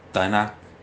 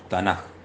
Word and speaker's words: Tanaj: Y muchos dirán, pues tendría que pronunciar Tanak, pero no, porque la Kof cuando cae en posición final suena como J. Entonces por eso no decimos Tanak, sino decimos Tanaj, pronunciando bien ahí esa letra J.